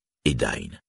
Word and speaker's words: Edain: AI se prononce comme l'anglais I ou à la rigueur le français I. Le nom Edain doit donc se dire Edain.